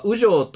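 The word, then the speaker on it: 烏城: ボラボラの中で出てきた雨城という言葉は、雨城と読み、あ、カラスの城ですね。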